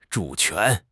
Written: 主权

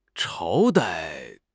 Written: 朝代

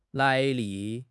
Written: 来历

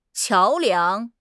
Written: 桥梁

